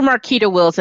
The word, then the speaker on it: Marketa Wills: It's Dr. Marketa Wills, and it's certainly my pleasure to be here.